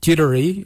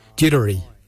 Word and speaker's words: Djerdari: Dederi is spelled D-J-E-R-D-A-R-I. Dederi, brown, Dederi.